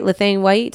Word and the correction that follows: Lena Waithe: Did I say her name right? – No, you did not. – Okay, how do you say her name? – Lena Waithe.